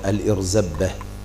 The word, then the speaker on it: الإرزبة: نعم، سؤال شيخ الإسلام عن الذبيح، فائدة في المصباح المنير، الإرزبة بكسر الهمزة مع التثقيل، قال الإرزبة والجمع أرازب، وفي لغة مرزبة بميم مكسورة مع التخفيف، يعني إن نطقناها مرزبة نقول هكذا مرزبة، لكن احنا ننطقها ايه؟